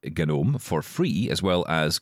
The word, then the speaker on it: GNOME: It's spelled genome, or gnome, I guess, but it's pronounced GNOME, according to Mark here.